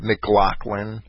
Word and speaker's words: McLaughlin: That those L-A-U-G-H and L-O-U-G-H names, that they were always pronounced Lock, like McLaughlin.